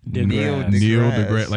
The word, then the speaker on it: Neil deGrasse: Neil deGrasse Tyson. – Neil deGrasse? – Neil deGrasse. Like you're kneeling on the grass? – It's Neil deGrasse.